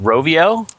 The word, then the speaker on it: Rovio: I think it's pronounced Rovio from Finland. – Yeah, yep.